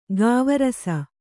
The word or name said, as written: ಗಾವರಸ